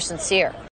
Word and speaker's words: sincere: She thinks you're sincere. – Sincere, okay? Sincero, okay? Nota la acentuación. Sincere. Enfatizando la segunda sílaba.